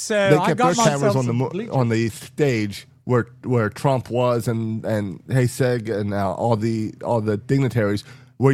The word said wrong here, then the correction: Hegseth: You mean Hegseth? – So, yeah. Well, yes. Did I say it wrong? – Hegseg.